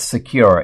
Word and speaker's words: Sekur: I want to tell you about Secure. It's spelled S-E-K-U-R.